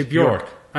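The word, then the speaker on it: Björk: Or Bjorn. – Bjork or Bjorn? – Oh, did I say it wrong? Bjork? – Yeah. – Okay, Bjork.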